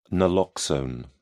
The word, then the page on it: naloxone: British English